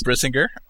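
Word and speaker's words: Brisingr: Aragorn Eldest Brisinger, is that how it's pronounced? – Yep, it's Brisinger. All right.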